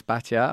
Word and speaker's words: Batya: I'm a fan of yours, Batya.